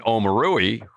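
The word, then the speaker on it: Omoruyi: Kind of what Eugene Omori. I'm calling him by Cliff's pronunciation, Eugene Omorui, who unfortunately, you know, he made the Mavericks and then unfortunately had a foot injury that required surgery.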